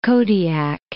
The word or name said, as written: Kodiak